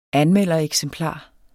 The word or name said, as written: anmeldereksemplar